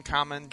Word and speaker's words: Kamen: of Common America, which is Cayman, Common, K-A-M-E-N, like Common Rider.